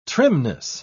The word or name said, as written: trimness